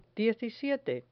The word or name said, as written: diecisiete